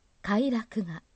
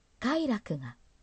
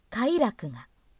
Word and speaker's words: 快楽: 快楽という単語は、平板型と頭高型の 2 種類のアクセントがあります。平板型で読むと、快楽が、頭高型で読むと、快楽がとなります。試しに、他の 3 つのアクセントでも読んでみます。中一高型だとすると、快楽が。